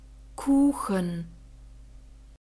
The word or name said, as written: Kuchen